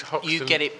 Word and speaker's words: Hoxton: We are at the Hoxton, do I ever get the name right? It is Hoxton. – You're getting it right more often.